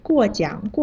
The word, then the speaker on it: 过奖: 啊，嗯，我明白了，志敏，你要说的应该是过奖过奖，过是四声，奖是三声。你刚才说的是果酱，果是三声，酱是四声，声调反了。